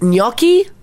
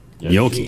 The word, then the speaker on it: gnocchi: In Italy, it's gnocchi. – Oh yeah, that one trips me up. Gnocchi? – It's spelled G-N-O-C-C-H-I.